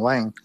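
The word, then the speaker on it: Huang: You know, if Jensen Wang, that's the right pronunciation, Wang,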